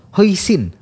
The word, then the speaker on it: hoisin: Dan ini disajikannya biasanya pakai lembaran pancake tipis, jadi ada irisan daun bawang, terus ada timun sama saus hoisin.